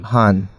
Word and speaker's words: Han Oak: Is it Han or Han? Han. That's yeah, good. Han Oak. Well, I got that one right. Yeah. Gary said Han.